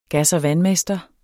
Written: gas- og vandmester

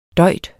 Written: døjt

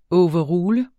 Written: overrule

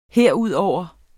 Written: herudover

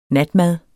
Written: natmad

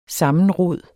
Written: sammenrod

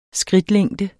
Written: skridtlængde

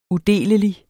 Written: udelelig

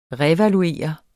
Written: revaluere

